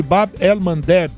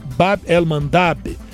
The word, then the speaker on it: Bab el-Mandeb: E aí, com a construção do canal de Suez, o estreito de Bab el-Mandeb, ou Bab el-Mandab,